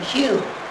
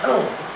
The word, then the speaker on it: Hume: and he found that if he wanted those Englishmen to call him Hume rather than Home, he had to spell it H-U-M-E, so he changed the spelling. So if you wonder, why does he spell it H-U-M-E while all those other guys spell it the wrong way?